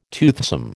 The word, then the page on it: toothsome: American English